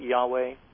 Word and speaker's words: Yahweh: like Y-H-W-H, pronounce that Yahweh, which is, again, a form of the verb I am or to be.